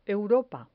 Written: europa

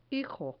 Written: hijo

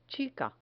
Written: chica

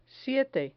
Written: siete

